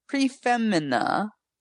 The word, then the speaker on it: Prix Femina: You don't pronounce the X in P R I X, right? It's pre, right? – Yeah. – Prefemina.